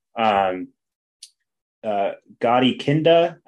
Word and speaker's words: Gadi Kinda: Gadi Kinda, I believe that's how you say his name. It's not kinda, which is kind of the slang term that looks like, but he's going to be out.